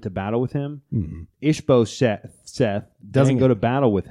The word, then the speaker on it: Ishbosheth: Ishbosheth. – Ishbosheth. You just said it perfectly.